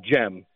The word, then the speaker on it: Cem: it's spelled C-E-M, it's pronounced Jem.